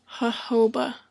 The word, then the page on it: jojoba: /həʊˈhəʊbə/; /həˈhoʊbə/; /hoʊˈhoʊbə/